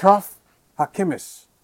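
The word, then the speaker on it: Achraf Hakimi: Achraf Hakimis.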